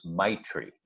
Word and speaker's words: Maitri: I believe the word is Maitri. I think that's how it's pronounced. M-A-I-T-R-I, Maitri.